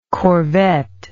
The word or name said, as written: corvette